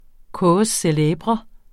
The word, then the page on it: cause célèbre: ˈkɔːs səˈlÆːbʁʌ; ˈkoːs -